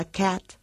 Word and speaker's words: cat: La A en la palabra cat, gato, se pronuncia con, bueno, lo que llamamos la llamada A sonriente. Y el nombre, bueno, digamos gracioso que tiene, se debe a la forma en la que ponemos la boca cuando la pronunciamos. Esa A de cat empieza su articulación como una A y termina como una E.